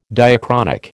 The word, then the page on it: diachronic: American English